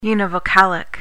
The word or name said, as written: univocalic